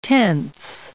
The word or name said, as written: tenths